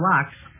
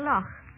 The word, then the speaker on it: Loch: You don't mean to tell me you're pronouncing Loch Lock? – That's on this program, we're pronouncing Loch Lock. – Where is my dead body? – If you will just stay alive for about another 12 minutes, you may do whatever you wish after this program is over. Couldn't you say, we say hastily Loch Lomond over here. How do you say it? – Loch.